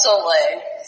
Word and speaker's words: Soleil: It's Soleil. So it's French for sun.